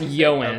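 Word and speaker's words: Ioan: Yoan? I think it's pronounced Yoan.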